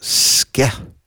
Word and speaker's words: sceith: P-I-E. – Yeah, it's back to Irishy. S-C-E-I-T-H, pronounced skeh.